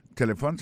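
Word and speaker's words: telephone: Téléphone, c'est comme ça on dit téléphone en anglais. On te répéter l'encore pour nous, téléphone. N'a pas remarqué que l'accent tonique l'a tombé sous premier syllabe là.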